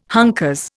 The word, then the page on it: hunkers: Indian